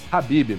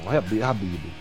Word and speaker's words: Khabib: Tem um cidadão que o nome dele eu não sei pronunciar, mas é alguma coisa em torno de Khabib Nurmagomedov. – Khabib, meu irmão. Khabib. – Khabib, né? O nosso querido Khabib. Hoje, ele é o namoradinho do Dana White. Não tem a namoradinha do Brasil? O Khabib é o namoradinho do Dana White.